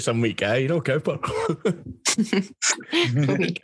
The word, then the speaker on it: Cowper: The Cowpers.